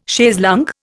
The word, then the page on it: chaise longue: Indian